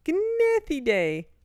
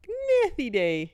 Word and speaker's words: Gnathiidae: Using lights to attract organisms in shallow water in 2021, Sickle and other researchers attempted to collect Gnathidae, which is spelled G-N-A-T-H-I-I-D-A-E. Gnathidae. – Is that once a year? – Tiny larvae. – Gnathidae.